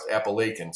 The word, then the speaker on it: Apalachin: And uh, unbelievably, I grew up next to a town named Apalachin, New York. Really? Spelled the same way, I believe, but that's how it's pronounced, Appalachian.